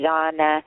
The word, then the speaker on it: Jnana: So this is it's pronounced so many different ways, but Jan or Jana, I pronounce it Jana.